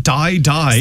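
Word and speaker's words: Dai Dai: She's called it Die Die. – So it's D-A-I-D-A-I, pronounced obviously. For ourselves, as die die.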